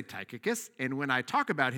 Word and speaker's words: Tychicus: So the main rule when you're reading the Bible is you pick a pronunciation, you say it with confidence, and you say it the same way. So Tychicus is what it's going to be.